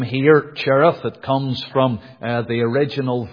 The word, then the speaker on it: Cherith: "Hide thyself by the brook Cherith," that is before Jordan. And the Jewish way would be to pronounce it Kereth, the CH being the hard pronunciation.